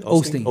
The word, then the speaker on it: Osteen: That's how you say his name, Osteen? – Osteen.